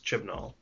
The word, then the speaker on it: Chibnall: Looks like Chibnall. – Sure, Chris Chibnall.